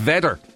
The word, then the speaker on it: Vedder: No, he's saying it's not Vader. He's saying it's Vedder.